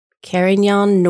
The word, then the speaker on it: Carignan: This variety is 100% Carignan Noir. I think that's how we're going to pronounce that. Carignan Noir. It looks like Carignan, but that's not how you say it.